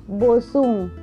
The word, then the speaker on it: boatswain: Glossary: Boatswain, a supervisor on a ship, pronounced bosun.